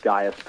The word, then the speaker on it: Gaius: And of all the words that you stumbled over, you pronounced Gaius perfectly.